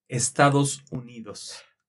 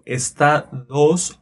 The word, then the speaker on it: Estados Unidos: Estados Unidos. – Estados Unidos. – Estados, remember the vowels, A, E, I, O, U. So, Estados, O, Estados Unidos.